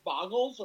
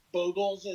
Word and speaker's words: Bogles: Do you think people are going to pick up Budokov's lead with Boggles or Bogles, as I'm told it's supposed to be called, but I still call it Boggles.